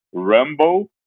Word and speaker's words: Rumble: Eu escolhi a faixa Rambo, Rumble, né? Para não, não vacilar na pronúncia brasileirada, né? Que não precisa.